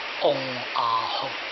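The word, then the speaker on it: 嗡 阿 吽 (ॐ आः हूँ): 啊，这三个字是用梵文写的，啊，它的发音叫嗡阿吽。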